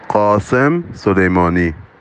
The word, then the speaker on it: Qasem Soleimani: Aber hört einmal kurz rein, wie man den Namen denn jetzt wirklich korrekt ausspricht. – Qasem Soleimani.